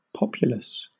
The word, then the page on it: populous: British English